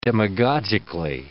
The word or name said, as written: demagogically